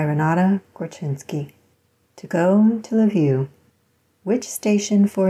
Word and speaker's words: Lviv: I think in Ukraine, which is where it is now, it's Lviv, which sounds like love you. And supposedly the American pronunciation is Lviv, which is hilariously not what it should be.